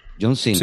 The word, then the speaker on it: John Cena: John Cena.